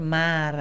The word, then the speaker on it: Mar: me llamo mejor Mar, así como el mar.